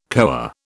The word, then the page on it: koa: British English